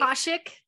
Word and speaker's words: Akashic: the Ashkanic records. I hope I said that right. – Akashic, Akashic, Akashic.